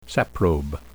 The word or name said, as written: saprobe